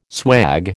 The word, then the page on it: swag: American English